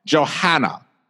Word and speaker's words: Johanna: Johanna. And it's like Joanna. That's how it says. I know there's an H in it, but we're saying it Joanna.